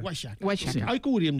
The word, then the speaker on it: Oaxaca: 1519, doncs 1529, unes monges carmelites de Oaxaca, Oaxaca, no sé com s'ha de pronunciar. – Oaxaca s'ha de pronunciar. – Com? – Oaxaca. – Oaxaca. – I en castellà antic, Oaxaca.